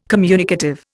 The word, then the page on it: communicative: Indian